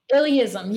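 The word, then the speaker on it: illeism: Illyism? – Illyism. Yeah. That's so funny. I've gotten so many questions about that word. It's I-L-L-E-I-S-M.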